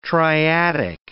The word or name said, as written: triadic